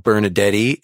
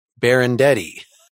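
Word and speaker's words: Benardete: This is, I've been saying it wrong. It's not Bernadette, it's Berendetti.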